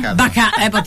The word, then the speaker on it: Bakanova: Siamo in diretta, niente poco di meno che Ekaterina Bakanova. Ho detto bene? – Bakanova.